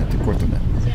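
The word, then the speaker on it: Ittoqqortoormiit: And that is really how it's spelled, but it's pronounced Itakurtumit.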